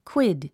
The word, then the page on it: quid: kwíd; クイド